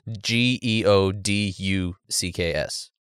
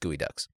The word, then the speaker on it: geoducks: It's spelled G E O D U C K S. So like geo ducks, but it's pronounced gooey ducks.